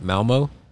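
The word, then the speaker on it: Malmö: It was like a tunnel underneath the ocean, and we came out in, probably pronounce it wrong, but Malmo? – Right, yeah.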